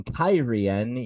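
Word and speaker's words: Cairhien: Chapter 37, Fires in Kyrian. Yes, that's apparently how that city is pronounced now.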